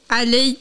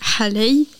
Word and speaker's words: halay: Le mot halay, également prononcé Alei, Halei ou d'une autre façon en fonction des régions.